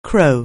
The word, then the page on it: crow: anglais